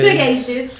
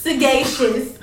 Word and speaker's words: sagacious: It means she said sagacious. – Sagittarius. – Sagacious. – How you spell it? – S A G as in George. A C as in cat. I O U S. Sagacious.